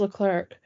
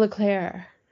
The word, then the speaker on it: Leclerc: But they don't, when they do the announcements for the race, when they say, you know, what drivers are, is it's, I say Charles Leclerc, it's Charles Leclerc. Charles Leclerc. – Charles Leclerc. – Yeah. – Spelled the same way, pronounced differently.